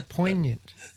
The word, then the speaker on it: poignant: The G in poignant is silent. Try poignant.